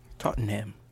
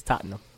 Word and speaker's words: Tottenham: Tottenham. Tottenham Hotspur. – I was going to do an accent. It was going to be really bad. – It's Tottenham.